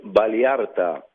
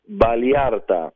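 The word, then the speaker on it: Vallarta: Pesach in Valiarta. – Valiarta. I don't know how to pronounce that. – I'll teach you. Listen to me. Pesach in Valiarta. – Valiarta.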